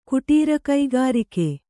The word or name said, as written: ಕುಟೀರಕೈಗಾರಿಕೆ